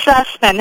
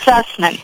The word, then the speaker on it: Zussman: It's Zussman. Everybody says Zussman. It's Zussman.